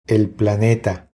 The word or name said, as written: El planeta